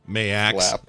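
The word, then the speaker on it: Maax: Mayax.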